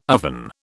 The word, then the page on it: oven: British English